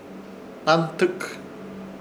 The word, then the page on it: Hnantɨk: Ishir Chamacoco